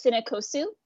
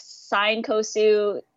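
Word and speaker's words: Cinecosu: um, so how I pronounce it is cinekosu. Ah, that's how I pronounce it. – But I've I've heard so many. I've heard like Sinekosu, sinesco.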